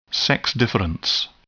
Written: sex difference

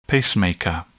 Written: pacemaker

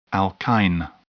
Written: alkyne

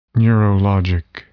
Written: neurologic

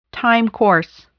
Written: time course

